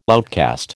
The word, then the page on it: outcast: American English